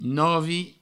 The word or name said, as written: novi